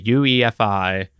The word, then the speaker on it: UEFI: This comes from Kaspersky's research team, where they just published their findings on a UEFI, I did say that right, yes, malware variant that they've dubbed Mosaic Regressor.